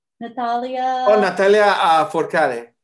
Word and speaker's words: Natalia Lafourcade: Natalia Lafourcade.